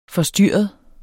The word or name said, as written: forstyrret